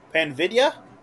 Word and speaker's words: Panvidya: Magnus, I'm going to butcher your last name, Panvidya? – No, you actually got it right.